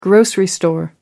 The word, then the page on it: grocery store: British English